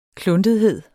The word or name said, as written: kluntethed